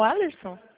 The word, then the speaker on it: Alerson: Falou com o Alerson? – Bom dia. Isso. – É assim que se pronuncia mesmo, Alerson? – É isso.